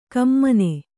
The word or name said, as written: ಘಮ್ಮನ